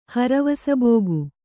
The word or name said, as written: ಹರವಸಬೋಗು